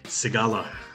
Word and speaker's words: Sigala: Did I say that right? – Sigala. – Sigala, dang it. Okay, I guess I needed to think more with a Hispanic slant. Sigala, right? – It's all good, yeah.